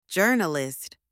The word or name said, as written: journalist